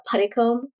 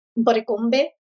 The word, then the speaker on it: Puddicombe: I feel like the Filipino pronunciation of Puddicombe. I heard everyone say this. Puddicombe. So they literally spelled it the way they pronounced it. – Love it. – So our last name is P-U-D-D-I-C-O-M-B-E.